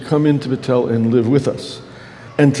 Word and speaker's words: Betel: Betel means Bethel. It's a Spanish form of the house of God. You don't pronounce the H in Spanish.